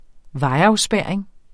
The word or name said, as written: vejafspærring